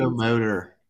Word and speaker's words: psychomotor: Psychometer agitation. Oh my God, I should check these. – Psychomotor So yeah, I see.